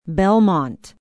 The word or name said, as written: belmont